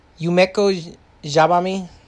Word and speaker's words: Yumeko Jabami: Uh, with Yumi- Yumiko Jabami. Yumeko Jabami.